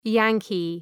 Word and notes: Yankee: ‘jæŋkı

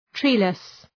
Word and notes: treeless: ‘tri:lıs

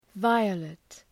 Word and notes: violet: ‘vaıəlıt

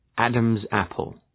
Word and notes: Adam’s apple: ‘ædəmz,æpəl

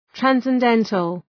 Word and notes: transcendental: ,trænsen’dentəl

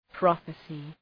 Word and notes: prophesy: ‘prɒfı,saı

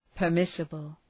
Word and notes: permissible: pər’mısəbəl

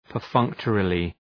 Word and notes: perfunctorily: pər’fʌŋktərılı

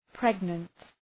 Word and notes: pregnant: ‘pregnənt

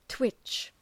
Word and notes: twitch: twıtʃ

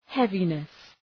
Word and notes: heaviness: ‘hevınıs

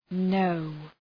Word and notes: know: nəʋ

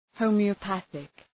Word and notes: homeopathic: ,həʋmıə’pæɵık

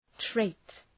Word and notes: trait: treıt

